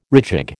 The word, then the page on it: rejig: American English